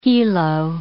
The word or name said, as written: helo